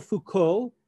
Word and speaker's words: Foucault: But let me introduce Michel Foucault. His last name is spelled F-O-U-C-A-U-L-T, Foucault, the pronunciation.